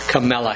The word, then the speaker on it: Kamala: And by the way, I had to look up exactly how you pronounce Kamala Harris. So I even pronounced it wrong. It's in the internet it says comma. It's like comma. Kamala.